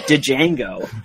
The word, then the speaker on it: Django: The other example of that joke not playing for me is in Django Unchained, where someone where Django has to tell someone that the D is silent, but the guy wasn't looking at his name. He wouldn't see a D and just say Django when he'd only ever heard people say it out loud.